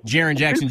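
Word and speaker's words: Jaren Jackson: Jaron Jackson from Michigan State? – Jaron Jackson Jr., yeah.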